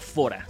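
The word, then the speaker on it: Fårö: es, sueco, se pronuncia Fora, se escribe ah. Faro.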